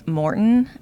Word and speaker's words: Maurten: And then Morten, I think that's how you say it. It's like M-A-U-R-T-E-N. – Yeah, yep.